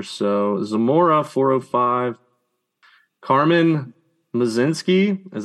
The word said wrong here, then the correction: Mlodzinski: Mlodzinski. – Mlodzinski? – Mlodzinski. You have to mold the M, okay, and the L together.